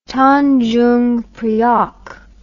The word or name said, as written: tandjungpriok